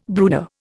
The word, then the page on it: brooder: Indian